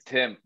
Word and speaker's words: Thimm: It's Thimm. The H Thimm. Is silent.